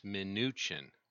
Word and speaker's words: Mnuchin: The name pronunciation has definitely been befuddling, but apparently he pronounces it Mnuchin. – Okay, then I'll get used to pronouncing it Mnuchin. – Mnuchin, like minutia.